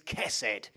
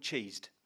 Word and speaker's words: chesed: That word there is chesed.